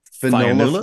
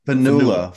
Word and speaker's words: Fionnuala: How do you pronounce the last one then? Is it Fionnuala? – Fionnuala Flanagan. – Fionnuala? – Fionnuala. Fionnuala.